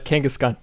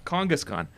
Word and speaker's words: Kangaskhan: Am I saying it right now? You want me to elongate the A's? – Yeah, you got it. Kangaskhan. – Kangaskhan.